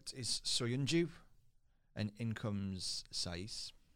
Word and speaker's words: Saiss: Saiss is normal. That's how you pronounce it.